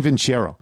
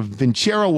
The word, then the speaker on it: Vincero: Go to Vincero Watches, V-I-N-C-E-R-O. And yes, I know that is not the correct Italianate pronunciation. I know that. We've been told that.